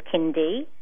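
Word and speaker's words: Kindi: My website is as in my name, so it's www, and then it's Kindy, which is spelled kind, K-I-N-I-N. So how am I saying that? K-I-N-D, but then there's an I on the end, so it's Kindy. And then my last name is Gill, G-I-L-L.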